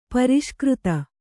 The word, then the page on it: ಪುರಸ್ಕರ್ತ: puraskarta